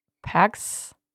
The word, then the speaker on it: PAX: Og ja, det udtales ikke pers, som jeg har udtalt det. Det er faktisk, øh, synes jeg selv, ret imponerende, at, øh, man kan være så meget på køre over noget og komme til byen, og så kan man ikke finde noget udtale i orden. Men, øh, men nu har jeg endelig fundet ud af, hvordan det udtales, og det er PAX.